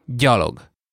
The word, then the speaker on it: gyalog: It's a little bit tricky, but basically you pronounce it the same way, just voiced. Gy. – Gy. Gy. – A good example would be gyalog, which means on foot. Gyalog. Gyalog. – And you spell this one G-Y.